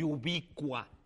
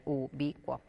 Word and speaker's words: ubicua: Lo primero que habría que decir es que se escriben sin tilde, porque aunque suena a esdrújula, en realidad no lo es, porque cuo y cua eh, es un dictongo y entonces en realidad es una, una palabra de ubicua, con tres sílabas.